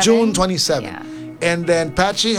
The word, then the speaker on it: Patxi: Patchy, that's a P-A-T-X-I. – P-A-T-X-I. – But it's pronounced Patchy.